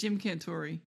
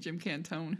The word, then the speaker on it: Cantore: It's Jim Cantore, isn't it? I've been saying Jim Cantone.